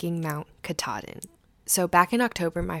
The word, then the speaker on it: Katahdin: It's Mount Katahdin.